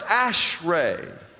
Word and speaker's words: Ashrei: The second Hebrew word is the word A S R E. Write that down. It's pronounced Ashray.